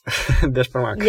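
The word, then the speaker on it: beshbarmak: How do you say it? Yeah, beshkamak. – Yes.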